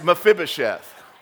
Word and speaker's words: Mephibosheth: Jonathan's son, now here's a good one for you, Mephibosheth. That's the English pronunciation. In the Hebrew, it's, but in the English, it's Mephibosheth.